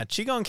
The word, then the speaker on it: Qigong: And then you started to lean into yoga and Qigong. Am I saying it right? – Yeah, Qigong came a little later.